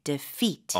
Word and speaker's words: defeat: 이음절인데 강세를 두 번째 음절을 주시면 좋아요. – 네. – 그래서 d 보다는 d 가볍게 defeat.